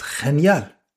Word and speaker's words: genial: Es genial. Es genial. Wie das deutsche Wort genial, nur das G vor einem hellen Vokal wie ein Ch ausgesprochen wird.